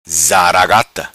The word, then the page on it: Zaragata: patua